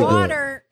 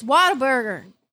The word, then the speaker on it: Whataburger: It's Whataburger. – What's a burger? – No. – Would you just say? – The H is silent. So just say that. Whataburger. Like you say with A.